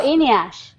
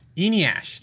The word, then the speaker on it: Enas: Can you pronounce the guy's name? – Which guy? – The guy. E-N-A-S. – Oh, Eniash. – Eniash. Thank you.